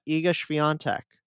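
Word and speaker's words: Iga Świątek: We're going to be talking about Iga Swiatek, who I have asked many Polish sources, and that is how you say her name. So if I'm wrong, you can refer to my Polish sources.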